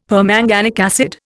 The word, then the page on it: permanganic acid: Indian